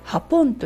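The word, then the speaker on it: Japón: なので例えば日本のことを書くときに、 J-A-P-O-N って書いて O の上にアクセントがつくんですけれども、これでハポンと呼びます。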